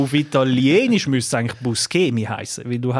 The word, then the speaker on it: Buscemi: Ja, Nachtrag zum Steve, wie man auch immer ausspricht, auf Italienisch, ich habe es wild geschaut, extra zu dem, auf Italienisch original hat er gesagt, spricht man schon aus, Buscemi, aber er ist irgendwie nicht so aufgewachsen und darum er selber nennt sich irgendwie Buscemi.